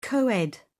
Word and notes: coed: British English

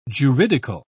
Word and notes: juridical: British English

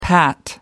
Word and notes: pat: American English

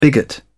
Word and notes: bigot: British English

